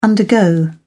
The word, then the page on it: undergo: British English